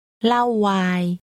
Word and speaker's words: เหล้าไวน์: It's pronounced with a falling tone followed by a middle tone. Say wine. – Lao wai.